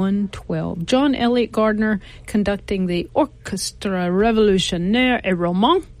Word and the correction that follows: Romantique: Did I say that right? – Et romantique.